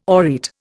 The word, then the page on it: aureate: Indian